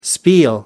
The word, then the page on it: spiel: American English